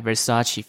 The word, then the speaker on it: Versace: When you see the V-E-R-S-A-C-E, you pronounce it Versace.